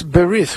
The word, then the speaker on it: berith: So it's called a bris, but in Hebrew, in the traditional pronunciation, it's berith, which means the covenant.